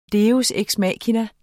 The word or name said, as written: deus